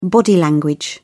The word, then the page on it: body language: British English